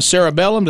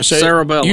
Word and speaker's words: cerebellum: Prior studies implicated the cerebellum. Did I say it right? – Yes. – Cerebellum.